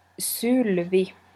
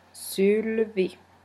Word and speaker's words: Sylvi: The correct pronunciation about my cardigan in Twist Collective Winter 2008 is Sylvi. That's my grandmother's name, and it's Finnish pronunciation. In English you might say Sylvi or Sylvia, but in Finnish it's Sylvi.